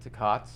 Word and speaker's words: Takats: And those are reared at Takats, and people have heard of, I should pronounce it, Takats is the closest Native Alaska pronunciation of it.